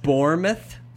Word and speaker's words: Bournemouth: But yeah, Stoke, Sunderland, AFC Bournemouth, or however it's actually pronounced. – Bournemouth, apparently.